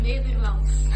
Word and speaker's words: Netherlands: Sí, Netherlands.